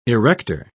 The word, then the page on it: erector: British English